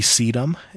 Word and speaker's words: sedum: One of the best, if you could manage the water, once you get it established, it actually does not like water, would be sedum. It's spelled S-E-D-U-M. – S-E-D-U-M.